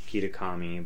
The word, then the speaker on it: Kitakami: I think it's Kitakami, but I'm probably mispronouncing that. – I think that's right. That sounds right.